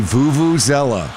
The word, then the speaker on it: Vuvuzela: Yes, those would be the Vuvuzelas. – Yes, the Vuvuzela. And some people pronounce it Vuvuzela.